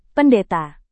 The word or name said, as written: pendeta